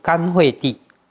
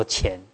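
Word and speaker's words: 干慧地: 佛说菩萨具足甘慧地，这个要念作甘哦，不能念作钱。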